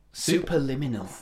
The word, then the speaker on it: Superliminal: I don't know if you guys have played Superliminal. – Superliminal. – Is that, have I said that right? – Yeah, that's right, yeah.